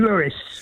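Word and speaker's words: Louis: But Tim, Satchmo pronounced his name Lewis.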